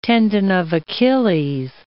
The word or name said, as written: tendon of Achilles